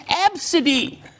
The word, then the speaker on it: Abcde: Yes, you pronounce it Absidy.